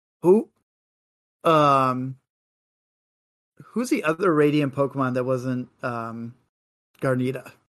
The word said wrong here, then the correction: Gardevoir: I said her name wrong. My God. – Gardevoir? – Yeah, sorry, Gardevoir. Well, who's Steelix, the other one? – Steelix and Sneasler.